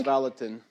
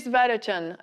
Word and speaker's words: Vallotton: Donc le titre de ce livre, c'est « La guerre des pensées » de Chris Vallotton, qui est pasteur, je pense qu'il faut le prononcer à l'américaine, hein, puisqu'il est pasteur à Bethel. Vas-y. – Chris Vallotton. – Chris Vallotton.